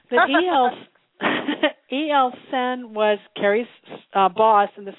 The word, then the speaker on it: Senn: E.L. Sen, uh-huh. And just for your listeners, that's spelled S-E-N-N, but he himself made plays on the fact that it sounded like sin, like, you know, something bad you shouldn't do.